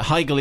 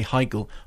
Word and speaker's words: Heigl: Heigl is pronounced with the I. It is an Austrian name. Whether she pronounced it like that, I don't know. And then confusingly, Mark in Manchester, Germans would say Heigl, Austrians would say Hegel.